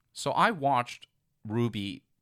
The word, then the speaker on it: RWBY: We're talking about Ruby, spelled R-W-B-Y.